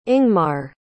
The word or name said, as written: ingmar